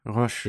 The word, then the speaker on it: Roche: I would pronounce it the French way, yeah, Roche.